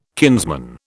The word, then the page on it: kinsman: British English